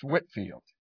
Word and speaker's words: Whitefield: It looks like Whitefield, but it's pronounced Whitfield.